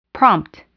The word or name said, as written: prompt